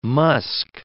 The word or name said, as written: musk